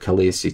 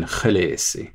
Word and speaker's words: Khaleesi: In the books, Khaleesi is spelt K-H-A-L-E-E-S-I. – You have an E-E and then an I. They can't be pronounced the same because they're spelled differently if I want to honor George R. R. Martin's spellings. That is, if something is spelled differently, it's supposed to be pronounced differently. – So the double E in the middle should not sound the same as the I at the end.